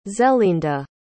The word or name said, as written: zelinda